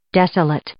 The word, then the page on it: desolate: adjective